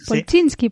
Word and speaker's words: Polchinski: Polchinski. – Polchinski, Polchinski, bueno, como sea.